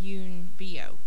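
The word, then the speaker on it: Yuen Biao: I believe it's pronounced Yoon Bio, which is the way it sounded when he said it in an interview.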